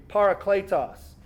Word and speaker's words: parakletos: And the Greek word is this, and I'll spell it for you, pronounce it for you, parakletos.